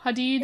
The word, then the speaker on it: Hadid: Um, do I say that right? I never know. Hadid? It's Hadid. Hadid. Hadid. I get it wrong every single time.